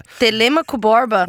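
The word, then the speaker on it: Telêmaco Borba: Eu acho que eu pronunciei de forma correta. – Telemaco Borba? – É.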